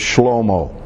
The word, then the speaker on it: Shlomo: The Hebrew word for Solomon, how you say it in Hebrew, is Shlomo.